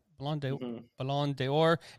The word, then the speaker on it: Ballon d'Or: Is that how you say it? It's French, so Ballon d'Or.